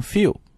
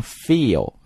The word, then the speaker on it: fuel: 注意这个单词的读法啊，要读成 fuel 不要读成 feel 啊。